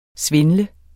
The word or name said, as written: svindle